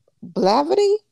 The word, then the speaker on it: Blavity: I think it's Blavity.